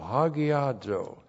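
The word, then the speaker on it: hagiazo (ἁγιάζω): Looks like that in the Greek, H-A-G-I-A-Z, long O, hagiazo.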